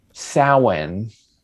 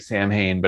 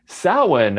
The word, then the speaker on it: Samhain: Well, this is an example of a Celtic word that's pronounced Samhain. Oh. oh, you wouldn't know that from how it's spelled, right? – Worse uh.